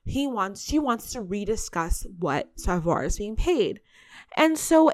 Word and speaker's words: Savoir: I also want to let you guys know that I just butchered a couple moments ago. It's Savoir is the PR firm and Maison Laveau is Antoine's company.